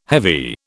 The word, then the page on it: heavy: British English